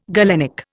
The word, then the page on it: galenic: Indian